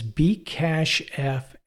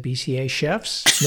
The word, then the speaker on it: bcachefs: Yeah, so there's a new file system that's been accepted into the Linux kernel, and it is bcachefs. It is bcachefs. And I've heard it, you know, on other Linux podcasts discussed, but in my first attempt to pronounce this, I said BCA chefs. No, it's bcachefs.